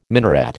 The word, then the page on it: minaret: American English